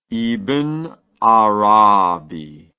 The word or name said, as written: ibn arabi